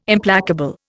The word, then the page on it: Implacable: Indian